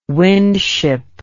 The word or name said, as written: windship